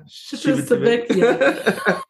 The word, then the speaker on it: Civitavecchia: The ship ports out of, and I'm going to butcher it, Civitavecchia. – Civitavecchia.